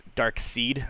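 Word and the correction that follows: Darkseid: Dark Side.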